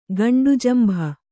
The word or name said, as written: ಗಂಡುಜಂಭ